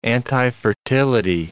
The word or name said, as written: antifertility